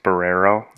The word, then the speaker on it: Barrero: I have Barreno. – I think it's pronounced Barrero. – Yes, so like burrito.